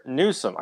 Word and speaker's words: Newsom: One, Governor Newsom, I said it right this time.